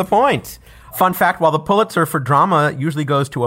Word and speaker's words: Pulitzer: People sometimes mispronounce the first syllable of this prize as Pule, but it's really Pull. – Okay, Pulitzer?